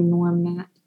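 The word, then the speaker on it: Gnorm Gnat: It was like Norm with a G. – Right. Norm. But that's a play on the way that Nat is spelled. So I think you're right. It's probably Norm Nat.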